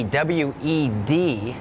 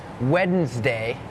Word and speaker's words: Wednesday: โล่งออกเหรอลูกโล่งใช่ไหมเห็นแล้วถอนหายใจเฮือกว่าเริ่มเลยก่อนเริ่มรายการพี่โบพูดถึงคำว่าอะไรน้องๆจำได้ไหมคะ Silent letters ใช่ไหมคะนั่นก็คือตัวอักษรที่ไม่ออกเสียงแต่อย่างคำนี้ค่ะเป็นคำหนึ่งที่มี silent letters Wednesday has that D W E D it's not wed Wednesday Wednesday D is silent